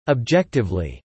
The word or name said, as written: objectively